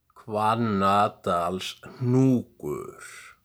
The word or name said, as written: Hvannadalshnúkur